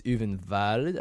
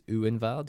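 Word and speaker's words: Ulvenwald: If it's German, it's Uvenwald, I think, because the W's are V's. Or maybe the V's are W's. Uvenwald.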